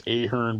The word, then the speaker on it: Ahern: It's spelled A-H-E-R-N. I assumed it was pronounced Ahern, but it's Ahern.